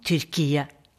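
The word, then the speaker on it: Türkiye: Vi sier Tyrkia, som ligger nært opp til den tyrkiske uttalen Tyrkiet.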